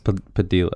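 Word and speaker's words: Padilha: I believe it's Padilla, because there's an H in there instead of two L's.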